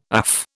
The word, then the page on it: AF: British English